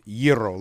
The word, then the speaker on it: gyro: I've done a lot of research, and all I find is it's gyro, like hero.